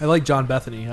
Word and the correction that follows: Bernthal: I think I said it right. – You mean Bernthal? – Bernthal.